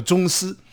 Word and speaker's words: 螽斯: 两只虫并列在冬天的那个冬的两点的那个地方，这个字呢读作中，中国的中，啊，丝呢就是像文哥这么斯文的丝，这这个虫呢叫中丝。